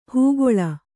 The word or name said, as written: ಹುಗುಳು